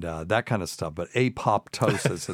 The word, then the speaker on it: apoptosis: But people will pronounce that apoptosis, and it's like, God, that just makes me cringe.